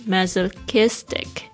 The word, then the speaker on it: masochistic: Number two, masochistic, 特别注意一下这几个发音哦，特别是 CH 这个声音是 K 的声音。